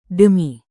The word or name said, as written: demi